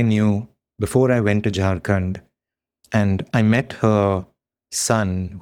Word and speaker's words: Jharkhand: Just to bring up one example of some of the phenomena you've just talked about, you go to Jharkhand, am I saying that correctly? To Jharkhand. – That's right.